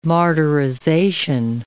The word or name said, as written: martyrization